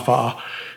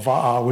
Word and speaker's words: va'a: And that's why if you look at the words in Hawaiian, like the word for canoe is va'a, which is W-A-A in Hawaiian. Doesn't use the alphabet exactly, but it's pronounced va'a. In Tahiti, it would be also called va'a, which is V-A-A, as they spell it, you know, Romanized or whatever it is.